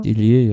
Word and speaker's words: Dillier: Habe ich das richtig ausgesprochen? Dillier? – Dillier, ja.